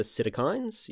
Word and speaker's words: psittacine: The first are the psittacines, you know them as parrots, and that's psittacine spelt with a silent P as in the word psychology.